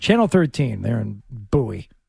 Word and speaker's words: Bowie: B-O-W-Y-E. – Yeah, it's not like David Bowie. It's Bowie. They pronounce it Bowie down there.